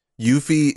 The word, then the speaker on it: Yuffie: Yuffie is what she says. It's also what the katakana would be pronounced as in Japanese.